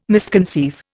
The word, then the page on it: misconceive: Indian